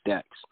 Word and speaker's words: Staxxx: My name is Ricky Staxxx, and you spell Staxxx with a money sign and 3 X's instead of a CKS.